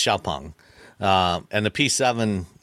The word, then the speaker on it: Xiaopeng: The proper pronunciation is Xiaopeng.